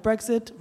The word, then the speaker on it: Brexit: Side note, Brexit rhymes with exit.